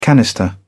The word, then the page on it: canister: British English